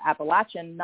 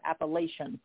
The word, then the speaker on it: Appalachian: In this part of the Appalachian Mountains, we call it Appalachian, not Appalachian.